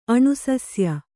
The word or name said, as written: ಅಣುಸಸ್ಯ